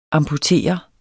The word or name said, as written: amputere